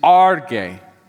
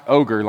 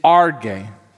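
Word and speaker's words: arge (ὀργή): The word here in Greek is arge. It's not pronounced ogre like it kind of looks on the screen, all right? It's arge.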